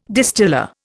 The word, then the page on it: distiller: Indian